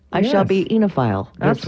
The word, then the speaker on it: oenophile: The word today is enophile. Enophile. Huh? And it's spelled O-E-N-O-P-H-I-L-E.